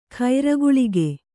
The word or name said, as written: ಕರ್ಕೋಲಕ